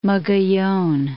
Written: Mogollon

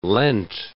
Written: leant

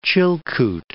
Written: Chilkoot

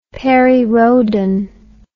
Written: perry rhodan

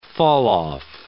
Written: falloff

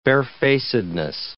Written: barefacedness

